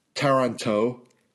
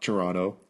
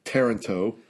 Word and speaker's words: Taranto: Then there's the Italian contingent led by Bohemond of Taranto, not Toronto. Taranto, and along with his nephew, Tancred of Hauteville.